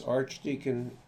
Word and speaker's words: Archdeacon: Thomas Archdeacon, A-R-C-H-D-E-A-C-O-N.